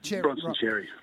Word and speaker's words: Bronson Xerri: Bronson Xerri.